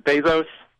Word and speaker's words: Bezos: But apparently, Bezos? – Yeah.